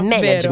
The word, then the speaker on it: management: Un Però siamo andati a cercare la pronuncia. – Vero, management, vero, perché c'è un po' questa diatriba sempre.